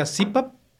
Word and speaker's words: CPAP: Se pronuncia CPAP. En la traducción correcta es CPAP.